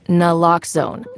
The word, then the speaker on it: naloxone: For the record, it's naloxone. Nuh, like, is naloxone addictive? Nuh-uh. Lox, like naloxone keeps humans safe, like locks keep bikes safe.